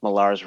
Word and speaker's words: Millar: Don't call him Miller. That's for sure. – I love. – Oh, for real? He gets psyched. – It's Millar.